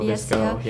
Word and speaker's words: VSCO: I use Visco. Is that how it's pronounced? – VSCO. Visco. Yeah.